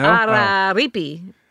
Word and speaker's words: Araripe: No, I got it wrong. – No? – Araripe. Oh. Araripe. I left a syllable out.